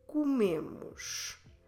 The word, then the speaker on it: comemos: Stressing the second to last syllable, me, nós, comemos.